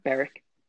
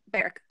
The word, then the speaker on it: Beric: that was the big one, because like when you look back on it, so Jorah is gone, Boric is gone, or Boric, or however you say his name is gone. – Beric. – Beric, thank you.